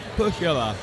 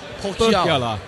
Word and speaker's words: Pohjala: And I'm going to let you tell us the right way to say the name of the brewery. – I'll do my best. – Okay. – So that's Pohjala. – Pohjala.